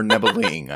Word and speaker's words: Nebelung: A Nebelung, or Nebeling, I think is how some people pronounce it.